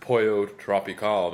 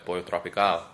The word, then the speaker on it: Pollo Tropical: Because she was like, make sure you pronounce Pollo Tropical. Not Pollo Tropical, but Pollo Tropical.